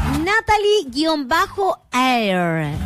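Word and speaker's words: Natalie Hair: Natalie-Air. Air. Natalie con I latina. Natalie-Air. Se escribe A, no, H-A, I latina, R. Así nomás.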